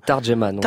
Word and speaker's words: Tarjuman: C'est comme ça qu'on prononce Tarjuman. – Tarjuman, voilà. Ça veut dire interprète tout simplement.